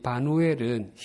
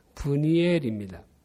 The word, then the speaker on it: 브니엘: 그녀의 아버지 바누엘은 히브리식으로 발음하면 브니엘입니다.